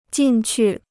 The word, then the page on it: 进去: jìn qù